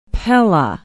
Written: pella

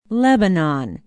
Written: lebanon

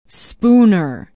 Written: spooner

